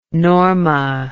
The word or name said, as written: Norma